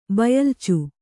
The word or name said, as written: ಬಯಲ್ಚು